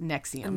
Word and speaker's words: NXIVM: So that's Nexium, and it's spelled, it's all caps, N-X-I-V-M. – Stupid. – But pronounced Nexium.